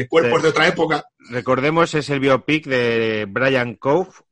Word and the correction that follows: Brian Clough: eh, no sé si lo he pronunciado bien. – Brian Clough.